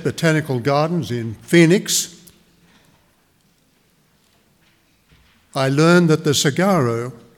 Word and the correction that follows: saguaro: if that's the way you pronounce it, the, cig- the saguaro cactus have little pores in their skin that collect carbon dioxide.